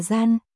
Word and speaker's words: Dhyan: Trong tiếng Pali Dhyan được phát âm là Gian, chính từ Gian mà thiền đã nảy sinh.